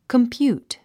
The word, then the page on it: compute: kəmpjúːt; コンピュート